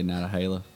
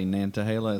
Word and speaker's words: Nantahala: North Carolinians, we say Natahala. – You're missing an N there.